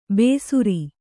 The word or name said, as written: ಬೇಸುರಿ